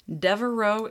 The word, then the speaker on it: Devereaux: Devereaux is French, so it is spelled D-E-V-E-R-E-A-U-X. Devereaux.